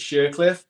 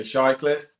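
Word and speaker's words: Shirecliffe: My mum's from, now again, she pronounces it Shercliffe, but people say Shycliffe.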